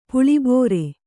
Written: ಪುಳಿಬೋರೆ

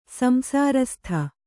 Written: ಸಂಸಾರಸ್ಥ

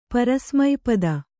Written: ಪರಿಸಮಾಪ್ತಿ

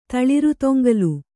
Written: ತಳಿರುತೊಂಗಲು

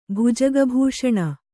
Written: ಭುಜಗಭೂಷಣ

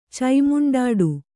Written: ಚೈಮುಂಡಾಡು

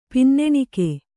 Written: ಪಿನ್ನೆಣಿಕೆ

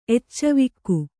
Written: ಎಚ್ಚವಿಕ್ಕು

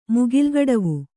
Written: ಮುಗಿಲ್ಗಡವು

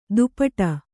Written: ದುಪಟ

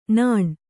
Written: ನಾನು

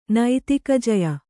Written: ನೈತಿಕಜಯ

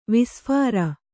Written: ವಿಸ್ಫಾರ

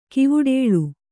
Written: ಕೈವಟ್ಟಲ್

